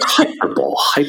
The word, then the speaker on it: hyperbole: And I was like, hyperbole. Oh, you mean hyperbole. And he was like, oh, is that how that's pronounced?